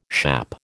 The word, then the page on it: schappe: American English